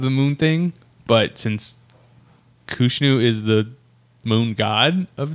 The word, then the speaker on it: Khonshu: Maybe now it's Iraqi Freedom, but he falls into a temple that was hidden, the temple for Khonshu. I don't know how you say it. However you say the Egyptian god. Khonshu? – Khonshu.